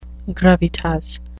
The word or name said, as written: gravitas